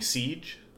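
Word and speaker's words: Ceej: People call me Siege.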